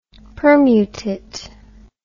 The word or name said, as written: permutit